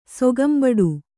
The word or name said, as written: ಸೊಗಂಬಡು